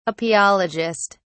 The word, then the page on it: apiologist: British English